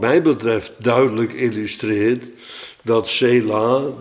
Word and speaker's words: Sela: Nou ja, dan in het Arabisch, maar dan met onze Romeinse letters staat er gewoon Sela, of Sala staat er geloof ik op de... En dan spreek je het nog in het Engels uit als Sela, dus het blijft hetzelfde.